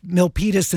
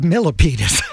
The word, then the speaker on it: Milpitas: Yeah, similar to the Bay Area city of Milpitas instead of Millipitas.